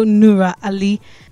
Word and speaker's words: Nura Ali: So today on Back to Base, we are getting to know Nura Ali.